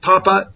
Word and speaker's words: Papa: Well, we've got a great guest for you on this program, this edition of the E-Factor, Jeff Papa, and I'm saying that right, Jeff, correct? Is that correct? – Yes, right. – Jeff Papa, PhD, Dr. Jeff Papa.